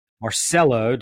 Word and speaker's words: Marcello: And we are happy to have Debbie Marcello.